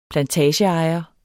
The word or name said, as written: plantageejer